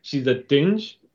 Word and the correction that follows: Jinn: Which is a Jinn. – A Jinn, sorry. Yeah. silent D, silent D at the beginning of it, just like Django.